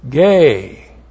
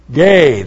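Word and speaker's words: Gay (γῆ): Gay. Not the gay that you think of. This is G-E. Gay.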